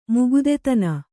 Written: ಮುಗುದತನ

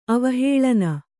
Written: ಅವಹೇಳನ

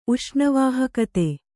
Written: ಉಷ್ಣವಾಹಕತೆ

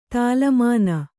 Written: ತಾಲಮಾನ

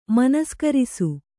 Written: ಮನಸ್ಕರಿಸು

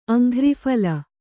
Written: ಅಂಘ್ರಿಫಲ

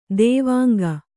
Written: ದೇವಾಂಗ

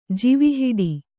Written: ಜಿವಿಹಿಡಿ

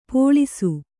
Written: ಪಳಿಸು

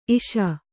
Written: ಇಷ

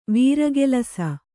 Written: ವೀರಗೆಲಸ

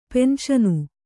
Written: ಪೆನ್ಷನು

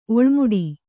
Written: ಒಳ್ಮಿಡಿ

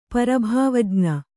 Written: ಪರಭಾವಜ್ಞ

